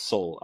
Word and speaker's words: Seoul: I'm making sure I'm saying that right. It's Seoul. I want to say Seoul every time I see it written down. It's Seoul, South Korea.